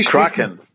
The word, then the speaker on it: Kraken: Ähm, aber, ich stelle dann fest, es gibt eine Mannschaft in Seattle, die heißen Craig, heißen sie, spricht man sie Kraken aus? Wie, wie Kraken. – Kraken, schon Kraken, Kraken ja, mit Kraken.